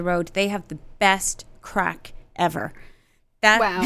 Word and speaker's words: craic: But C-R-A-I-C, spelt but pronounced crack, means fun in Ireland.